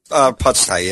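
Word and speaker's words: Pusztai: Is it Pustai? Did I say that correctly? – Pustai, yeah.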